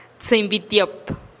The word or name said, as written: tsïinn bi tiop